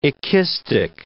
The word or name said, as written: ekistic